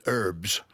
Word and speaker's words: herbs: They call it herbs. – Yes. – They don't pronounce the H.